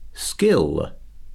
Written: skill

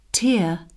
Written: tear

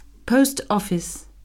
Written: post office

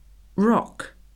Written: rock

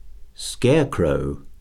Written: scarecrow

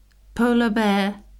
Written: polar bear

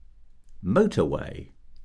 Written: motorway

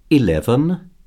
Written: eleven

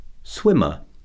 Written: swimmer

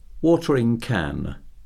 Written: watering can

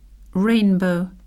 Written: rainbow

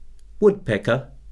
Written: woodpecker

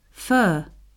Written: fir

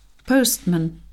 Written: postman

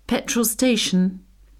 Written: petrol station

